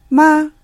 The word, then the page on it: 吗: ma